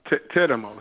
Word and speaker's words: Teramo: Teramo. It's called Teramo.